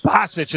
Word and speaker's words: Basich: That looks like it's Basics with the call. Bennett Basics. Basich, maybe. That's his first. Bennett Basich is the pronunciation.